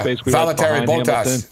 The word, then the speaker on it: Valtteri Bottas: How do you say? Bottas, the second place guy? – Yeah. Valtteri Bottas.